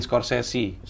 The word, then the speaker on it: Scorsese: Terus Bacanya sesi apa sisi situ? – Sesi. – sesi. Martin ya? – Scorsese.